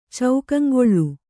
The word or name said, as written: ಚೌಕಂಗೊಳ್ಳು